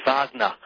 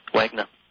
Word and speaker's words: Wagner: Well, I think you might recognize who he is if I pronounce his name as it was pronounced. It was Wagner.